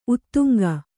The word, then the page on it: ಉದಂಕ: Kannada; udaŋka